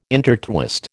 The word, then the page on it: intertwist: American English